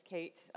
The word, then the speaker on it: Kate: My name is Kate. As I say to many of the middle school students that I have the pleasure to work with, it's easy to remember my name because it rhymes with debate.